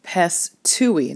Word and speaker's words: pisteuei: Now, when you're thinking about belief, again, the Greek word here is pistui, and I think I said that correctly. It is P-I-S-T-E-U-I, oh, and let me try that again, P-I-S-T-E-U-E-I, and that means to put one's faith or trust in someone or something.